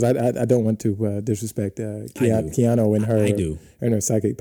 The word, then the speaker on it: Keanu: So are we going with Kino or Keanu? I don't know how smooth it is. – I I in the piece, it's actually phonetically pronounced. – Ah, you're right. – It is. So I am 90% sure I'm wrong, that it is Keanu.